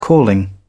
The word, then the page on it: calling: British English